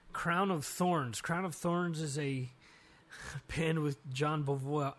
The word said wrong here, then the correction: Jean Beauvoir: Jean Beauvoir, if you want to pronounce it correctly.